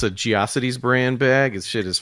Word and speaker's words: Geocities: Is it pronounced Jocities? What the fuck was that? – It's Geocities. – It's Geocities, then.